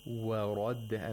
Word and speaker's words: وَرَدَّ: Wa radda. – And all three letters have fatha. – Mm-hmm. – So we have to make sure that only the ra is pronounced with tafkhim. Wa ra de.